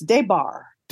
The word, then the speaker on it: DeBar: Michael DeBars, yeah, yeah. Great man. – The S is silent, by the way. It's DeBar.